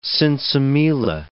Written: sinsemilla

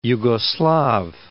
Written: Yugoslav

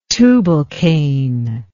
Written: tubalcain

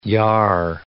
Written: yar

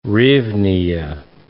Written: hryvnia

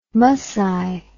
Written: musci

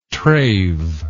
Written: trave

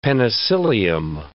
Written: penicillium

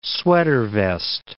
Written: sweater-vest

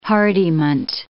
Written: hardiment

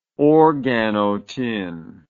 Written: organotin

